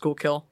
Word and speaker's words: Schuylkill: I'm happy you go with the tradition of the Native Americans, but we have words like Schuylkill here. I'm like, yeah, it's pronounced Schuylkill.